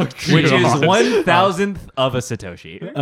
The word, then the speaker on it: Satoshi: One Satoshi, am I saying it right? – Satoshi.